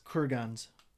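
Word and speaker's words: kurgan: Is it pronounced kurgans or kurgans? – Kurgans.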